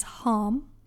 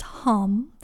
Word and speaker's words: Ham: The mantra is Ham. That's spelled H A M. Um, it's not ham, it's Ham. And uh, I, I've definitely checked that out to make sure, but it's spelled like ham, but yeah, it's pronounced hum.